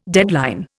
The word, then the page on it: deadline: Indian